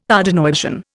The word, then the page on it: Tardenoisian: Indian